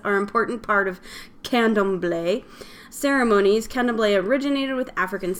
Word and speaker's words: Candomblé: Candomblé, is that what you said? Yeah. – Yeah, I said candomblé. – Okay, so it's... Yeah, there's no... – Candomblé. – Awesome. So you got it right.